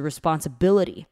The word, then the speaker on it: responsibility: responsibility，那我们念快一点，就是 responsibility，就是 T 常常都会变念成的音。